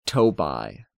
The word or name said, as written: Tobi